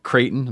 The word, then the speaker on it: Creighton: So Taylor Keen is a full-time instructor at Creighton.